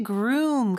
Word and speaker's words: groom: 자, 그리고 신랑이라는 뜻의 groom 같은 경우는 여기도 역시 room, 요게 발음이 잘 되면 앞에 g 소리만 넣어주면 되죠. groom, room이 더 크게 들려야 돼요.